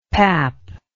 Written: papp

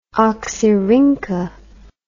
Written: oxyrhyncha

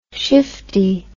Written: shiftie

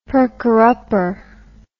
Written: perker-upper

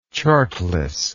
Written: chartless

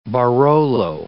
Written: Barolo

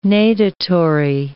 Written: natatory

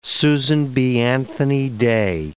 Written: Susan B. Anthony Day

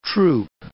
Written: troupe

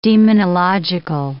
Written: demonological

